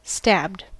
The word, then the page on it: stabbed: American English